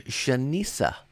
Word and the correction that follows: Shenseea: Shania. I'm sorry, Shensia. I said it wrong.